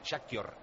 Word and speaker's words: Shakhtar: Insiste Pedrito Bardín que digamos Shakhtar, que es como se pronuncia.